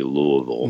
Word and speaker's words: Louisville: He was born in Louisville in 1908, or to pronounce it correctly, Louisville.